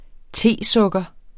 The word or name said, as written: tesukker